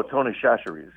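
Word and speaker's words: Tony Chachere's: Tony Chetri, is that okay? – What is it? – Tony Chetri? – Oh, Tony Chacheries, yeah. Tony, that's a good one. – Say it right.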